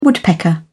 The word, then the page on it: woodpecker: British English